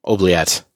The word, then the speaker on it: Oubliette: Is that how that's pronounced? Oublet? – Sounds right. – Yeah. Oubliette.